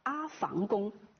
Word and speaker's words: 阿房宫: 在没有必要的情况下，我们不去改变一个字的普通话读音，这样两条原则共同作用之下，我们认为啊，还是读阿房宫更加的合适。